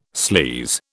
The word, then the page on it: sleaze: British English